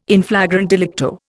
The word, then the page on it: in flagrante delicto: Indian